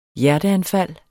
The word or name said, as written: hjerteanfald